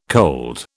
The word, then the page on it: cold: British English